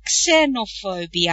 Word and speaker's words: xenophobia: We say xenophobia and it's spelt with an X in English, but you say xeno, sir.